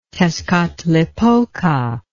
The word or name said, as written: tezcatlipoca